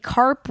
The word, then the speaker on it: Karp: You can find me at Ruby Karp with a K.